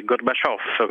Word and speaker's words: Gorbaciov: Allora, bisogna fare attenzione all'accento e alla S finale, Gorbaciov, come lo direbbero a Napoli,